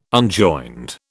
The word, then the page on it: unjoined: British English